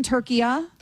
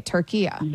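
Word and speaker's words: Türkiye: Okay, Turkiya. – There you go. That's perfect. That's how it's pronounced. It's always been pronounced that in Arabic language.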